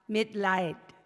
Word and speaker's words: Mitleid: The word is Mitleid. I think I'm saying that right. Mitleid. Mitleid. Thank you.